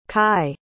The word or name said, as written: kye